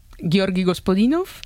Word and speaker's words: Georgi Gospodinov: A ver, Georgi Gospodinov, sí.